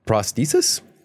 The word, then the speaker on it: prosthesis: Ted Berger is developing a prosthesis. Prosthesis? Am I saying that right? – Yeah.